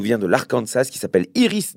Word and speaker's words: Iris: Iris, bah, comme le prénom, et Dement, ça s'écrit D-E-M-E-N-T.